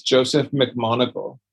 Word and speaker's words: Joseph McMonagle: And you sent me that video of Joe McNeagle. Is that how you say his name? – I think it's Joseph McMonagle.